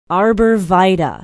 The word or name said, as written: arbor vitae